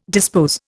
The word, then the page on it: dispose: Indian